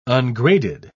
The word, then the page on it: ungraded: British English